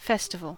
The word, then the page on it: festival: American English